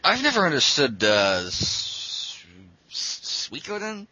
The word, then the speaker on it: Suikoden: Suikoden, it's I mean, you know, from the McDole thing you would think you know, holy shit.